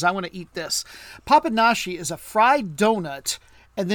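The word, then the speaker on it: Papanași: That's the capital, and this you pronounce this like an sh. This is Papanashi. You say that one? – Papanashi.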